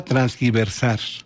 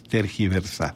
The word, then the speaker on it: tergiversar: ¿Transgiversado o tergiversado? – Sí, mucha gente usa transgiversar. Y en una de esas se va a aceptar con el tiempo, pero es tergiversar.